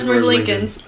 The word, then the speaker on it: Merzlikins: Elvis Merzlikins.